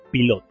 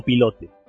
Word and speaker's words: Pilote: Y esa revista se llamaba Pilot o Pilote, como queramos pronunciarlo.